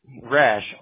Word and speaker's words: Rash: Rash, R-A-S-H.